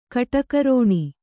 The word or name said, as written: ಕಡುಗರುಣ